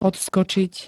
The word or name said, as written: odskočiť